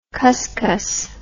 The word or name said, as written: cuscus